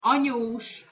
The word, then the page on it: anyos: Hungarian